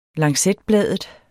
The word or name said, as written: lancetbladet